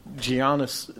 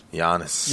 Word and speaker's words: Giannis: Giannis, number 9. Anthony Davis, 8. – Hey, man, that's disrespectful, man. Get his name right. – Giannis.